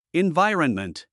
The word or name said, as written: environment